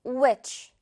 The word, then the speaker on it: which: 자, which 아니겠죠. 묵음으로 변해서 h가 which 이렇게 발음됩니다. which.